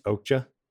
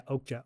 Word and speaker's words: Okja: It's pronounced Okja. – Yeah, Okja. O-K-J-A.